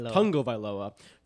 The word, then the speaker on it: Tagovailoa: I will be streaming Tua Tagovailoa. Did I say that right? – Tagovailoa versus the New York Jets.